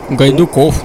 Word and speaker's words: Гайдуков: Гайдуков, ты сказал, ударение на букву О? – На. Гайдуков, на О, на последний слог.